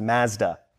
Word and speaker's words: Mazda: Sorry, in Canadian, that's pronounced Mazda.